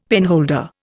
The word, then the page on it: penholder: Indian